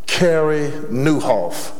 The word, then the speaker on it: Nieuwhof: One of the most prolific and powerful books that I have in my library is a book by Cary Newhoff. His last name is spelled N-I-E-U-W-H-O-F.